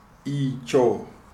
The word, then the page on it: Icho: Shona